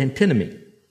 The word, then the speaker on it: antinomy: It's the word antinomy. Antinomy. A-N-T-I. N-O-M-Y, A-N-T-I-N-O-M-Y, antinomy.